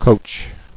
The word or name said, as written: Coaching